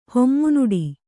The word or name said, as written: ಹೊಮ್ಮುನುಡಿ